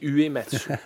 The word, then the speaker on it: Uematsu: Je sais pas si tu as, bien prononcé, mais. – C'est Uematsu.